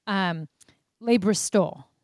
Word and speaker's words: Le Bristol: And so I feel like I'm going to say this hotel wrong. Artem says I always say it wrong. Le Bristol. I said it right that time.